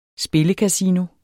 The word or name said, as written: SPILLEKASINO